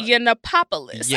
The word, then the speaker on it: Yiannopoulos: Yiannopoulos. – What the fuck? – Yiannopoulos.